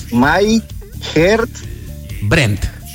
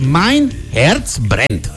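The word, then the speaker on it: Mein Herz brennt: Entonces, esta canción se pronuncia Mein Herz. A ver, repita de mí. – Mein Herz Brent. – Ahí está. Entonces, Mein Herz Brent. – My Herz Brent.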